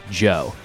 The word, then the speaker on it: DJO: He eventually leaves Post Animal and he does a solo project called Joe, spelled DJO. – The D is silent.